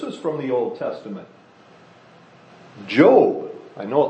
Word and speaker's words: Job: And so when the computer was reading back to me what I wrote, it said job. So it was pronouncing it job. It looks like job. It's the same word in English, but for the name, we pronounce it Job. It's pronounced Job.